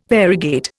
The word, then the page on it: variegate: Indian